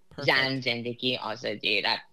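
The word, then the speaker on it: Zan, Zendegi, Azadi: Zan Zendiki Azadi, that's how you say it in Persian.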